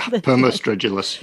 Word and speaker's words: Permostridulus: Am I saying that right? – Yeah, Permostridulus, yes.